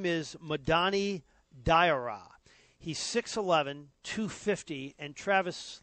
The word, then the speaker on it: Diarra: R-R-A. – That's just opening up teasing for five-year-olds. That's tough. – Diarra.